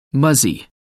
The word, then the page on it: muzzy: American English